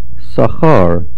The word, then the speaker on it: Sachar: Sachar. Sachar is milra. The stress is on the last syllable,